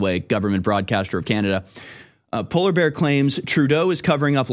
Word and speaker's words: Poilievre: Poilievre, like polar bear?